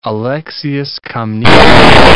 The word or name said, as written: Alexius I Comnenus